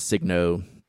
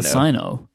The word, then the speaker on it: Signo: And is it a hard G or a soft G? UM1. Do we know this? – It's a hard, we do. It's because we used to call it the Sino. – Right, it's Signo. – Okay. – That's a for sure. We worked on this years ago. It's Signo. It took me a while to get my pronunciation changed, but it is Signo.